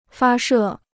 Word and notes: 发射: fā shè

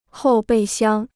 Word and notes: 后备箱: hòu bèi xiāng

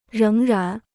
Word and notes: 仍然: réng rán

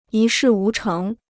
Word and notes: 一事无成: yī shì wú chéng

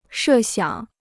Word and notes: 设想: shè xiǎng

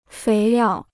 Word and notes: 肥料: féi liào

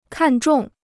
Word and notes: 看重: kàn zhòng

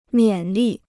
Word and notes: 勉励: miǎn lì

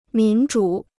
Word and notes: 民主: mín zhǔ

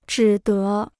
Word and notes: 只得: zhǐ dé

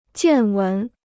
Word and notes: 见闻: jiàn wén

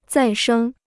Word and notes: 再生: zài shēng

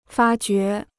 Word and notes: 发掘: fā jué

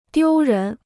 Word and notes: 丢人: diū rén

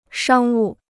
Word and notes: 商务: shāng wù

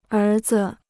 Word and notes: 儿子: ér zi

